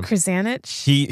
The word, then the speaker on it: Krzanich: The Intel CEO, Brian Krzanich, I don't know how you say his name. – Krzanich.